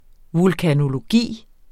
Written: vulkanologi

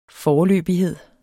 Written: foreløbighed

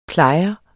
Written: plejer

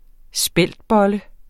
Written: speltbolle

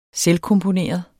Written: selvkomponeret

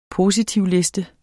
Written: positivliste

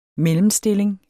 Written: mellemstilling

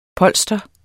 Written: POLSTRETS

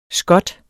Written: skot

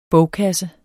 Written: bogkasse

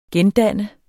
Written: gendanne

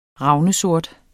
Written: ravnsort

